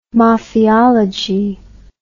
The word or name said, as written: mafiology